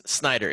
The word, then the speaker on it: Snyder: Dude, Snyder. It's Snyder. It's not that hard to pronounce.